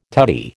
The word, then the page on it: tutti: American English